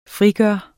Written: frigøre

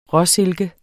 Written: råsilke

